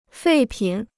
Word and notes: 废品: fèi pǐn

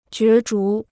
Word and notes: 角逐: jué zhú

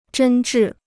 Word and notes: 真挚: zhēn zhì

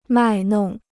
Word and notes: 卖弄: mài nong

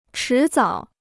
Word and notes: 迟早: chí zǎo

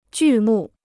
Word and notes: 剧目: jù mù

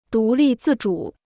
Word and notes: 独立自主: dú lì zì zhǔ

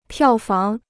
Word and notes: 票房: piào fáng

